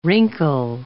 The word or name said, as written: wrinkle